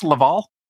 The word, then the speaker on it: Laval: is it pronounced Laval? – Laval. – Laval. – English Canadians pronounce it, but. – Okay, Laval.